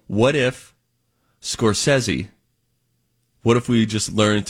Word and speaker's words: Scorsese: I think that's the proper pronunciation. I believe it's Scorsese.